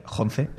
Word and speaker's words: Jonze: Spike Jonze, tal cual se escribe,